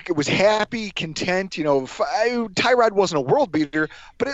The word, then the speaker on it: Tyrod: We call him Tyrod because that's how it's spelled. I mean, I know it sounds like a part of a car, but I can't do anything about that. That's what it looks like on paper.